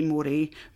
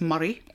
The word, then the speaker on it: Moray: Is it A, Angus, B, I better say this right, I would say Moray, but it's Murray.